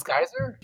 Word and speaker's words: Scizor: I mean, it's spelled Scizor, but everyone pronounces it Scizor, and so does the Pokemon Stadium 2 announcer.